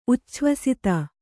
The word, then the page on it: ಉಚ್ಛ್ವಸಿತ: ucchvasita